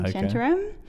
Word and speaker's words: Shantaram: So you probably will want to go to India after reading Shantaram. – How do you spell it? – S-H-A-N-T-A-R-A-M, Shantaram.